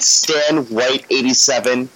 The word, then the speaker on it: Stan Whyte: On Twitter, you can find me at StanWhyte87. I know it sounds like the color white, but my last name is spelled with a Y, so type in WHYTE.